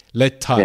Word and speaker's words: Lertad: The very Thai way to pronounce it would be Letad. So the emphasis on the tat. – Okay, Letad.